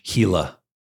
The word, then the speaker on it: Gila: Oh, with an H. Gila. – Gila. – Yeah. – But it's spelled G-I-L-A? – Correct.